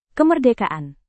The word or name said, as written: kemerdekaan